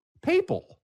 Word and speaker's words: papal: Wasn't it papel? Papel? How do you say it? – Papal. – Papal? – Yes.